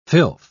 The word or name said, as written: filth